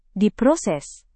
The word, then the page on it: diproses: Indonesian